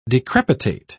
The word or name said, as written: decrepitate